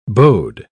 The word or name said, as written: bowed